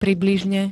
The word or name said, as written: približne